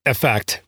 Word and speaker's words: EAffect: So I just said, I pronounce it as effect with the E and the A is silent.